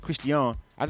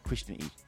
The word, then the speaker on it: CRIST'IANI: But it's CRIST'IANI, not CRISTIAN E.